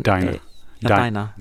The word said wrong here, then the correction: Dinah: Dinah. – Dinah's a girl's name.